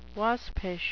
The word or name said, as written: waspish